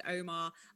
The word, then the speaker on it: UOMA: Okay, so my next one is a brand called Omar. It's spelled U-O-M-A.